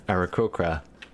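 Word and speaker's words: Aarakocra: the Arakokra. Am I saying that right? – You said it right.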